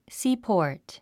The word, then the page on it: seaport: síːpɔ̀ː r t; スィーポート